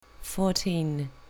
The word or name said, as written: fourteen